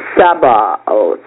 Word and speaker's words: Sabaoth: And the name for the Lord of Hosts is Jehovah Sabaoth. I don't know how to say it. S-A-B-A-O-T-H. Sabaoth.